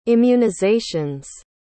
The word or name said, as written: immunizations